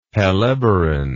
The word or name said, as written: helleborin